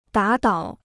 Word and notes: 打倒: dǎ dǎo

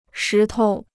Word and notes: 石头: shí tou

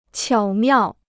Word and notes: 巧妙: qiǎo miào

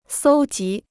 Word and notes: 搜集: sōu jí

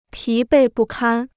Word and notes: 疲惫不堪: pí bèi bù kān

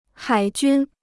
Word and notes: 海军: hǎi jūn

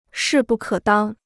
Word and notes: 势不可当: shì bù kě dāng

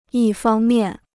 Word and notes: 一方面: yī fāng miàn

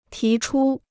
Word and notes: 提出: tí chū

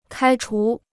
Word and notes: 开除: kāi chú

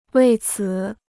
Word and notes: 为此: wèi cǐ

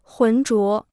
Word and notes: 混浊: hùn zhuó

